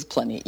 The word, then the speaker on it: Pliny: Yeah, the correct pronunciation for the philosopher Pliny the Elder is Pliny, yes.